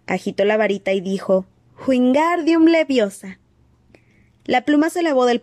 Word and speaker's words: Wingardium Leviosa: Wingardium Leviosa. gritó, agitando los largos brazos como un molino. Lo estás diciendo mal. Harry oyó que Hermione lo reñía. Es Wingardium Leviosa. Pronuncia gar más largo y claro.